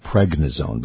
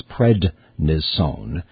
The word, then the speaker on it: prednisone: People often mispronounce it pregnisone, but it's prednisone, which is a steroid that is like a sledgehammer to the flare-up.